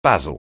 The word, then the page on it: basil: anglais